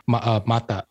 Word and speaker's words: mata: For us, I think it's the emphasis is on the first first syllable, mata.